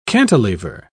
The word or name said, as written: cantilever